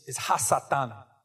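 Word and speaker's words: hasatan (הַשָּׂטָן): The Hebrew word for Satan is hasatan. Hasatan. Hasatan, like that.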